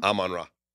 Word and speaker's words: Amon-Ra: How do you say his first name? – Amon-Ra. – Amon-Ra. – Yeah, like the Egyptian god.